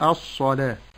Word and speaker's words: الصلاة: نعم ثم بعد ذلك نلاحظ هنا أن كلمة الصلاة رسمت الألف واوا وتنطق ألف، ووضع فوق الواو ألف الإشارة، وقد جاءت في ثمان كلمات، كلمة الصلاة، الزكاة، الربا، الحياة، النجاة، الغداة، المشكاة، مناة.